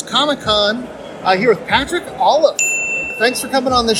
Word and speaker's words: Olliffe: Yes. Olive like olive, but with F sounds.